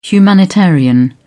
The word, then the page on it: humanitarian: British English